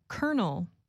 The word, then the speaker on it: colonel: Colonel is spelled C-O-L-O-N-E-L. So why do Americans say colonel as if it is spelled K-E-R-N-E-L?